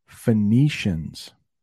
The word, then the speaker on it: Phoenicians: Phoenicians.